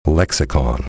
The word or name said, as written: lexicon